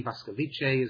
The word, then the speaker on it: Boskovice: German name, Boskovice, is how it's pronounced in Czech.